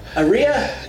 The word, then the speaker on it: Eria: Eria? – Yeah, you got it.